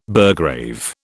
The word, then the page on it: burgrave: British English